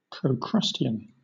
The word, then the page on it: procrustean: British English